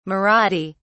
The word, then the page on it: marathi: British English